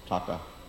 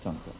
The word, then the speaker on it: taka: The rupee word is taka. So many taka, 300 taka. Tanka, the head of N, tanka, silent N.